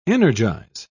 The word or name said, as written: energize